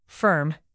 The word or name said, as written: firm